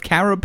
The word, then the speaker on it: carob: Is it pronounced carob? The 1 pound of carob powder, C-A-R-O-B? – That's good. C-A-R-O-B.